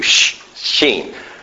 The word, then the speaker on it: shin: Okay, it's just the S-H sound. That's how you pronounce it in Hebrew, shin. A is a vowel sound that they add to it. Sha, S-H-A-M.